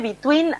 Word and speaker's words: Bituin: Sí, y se escribe Between así como suena. B-I-T-U-I-N.